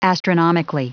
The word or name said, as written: astronomically